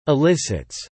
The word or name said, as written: elicits